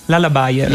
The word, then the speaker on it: Lullabier: Come si pronuncia? Lala Beer? – Lala Bayer. – Lala Bayer, perfetto.